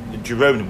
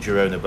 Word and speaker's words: Girona: Girona, we call it Girona, but I think it's probably pronounced Girona.